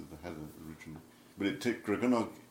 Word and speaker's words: Gregynog: The pronunciation of Welsh is always on the penultimate syllable, so it's Gregynog. A little bit like the Italian, Gregynog. – Not Gregynog, which is what you might... – Not Gregynog or Gregynog or anything like that. G-Y-N is always pronounced gun, so it's Gregynog, Gregynog.